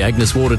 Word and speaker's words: Agnes Water: Down to Agnes Waters. – Mate, it's pronounced Agnes Water.